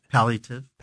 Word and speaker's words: palliative: Palliative.